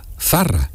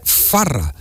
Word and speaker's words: Zarra: Don Matías Prat, es de Palma del Río, de Córdoba, y entonces no pronunciaba la Z porque se seaba, entonces Zarra es con Z, Zarra, y él sí, sí. – gritó y al mundo entero el segol de Zarra y decía Farra porque cambiaba la Z por la F.